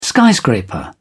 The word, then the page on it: skyscraper: British English